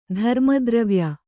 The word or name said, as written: ಧರ್ಮದ್ರವ್ಯ